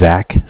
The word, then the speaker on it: Zach: So my name is Zach, spelled Z-A-C-H. – Okay. – I'm calling from the Bank of Cardiff, and Cardiff is spelled C-A-R, like car, D as in dog, I as in image, F-F as in Frank Frank.